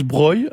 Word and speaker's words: Breuil: Mais en l'occurrence, Breuil, non, se dit bien Breuil, même s'il a un S. Et vous allez comprendre pourquoi, parce qu'en fait, sous l'orthographe Breuil sans le S,